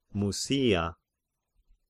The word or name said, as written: Mysia